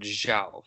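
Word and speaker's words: João: That would be Yao Felix, who plays for Benfica, and he is one heck of a talent. – I think it's pronounced Zhao Felix.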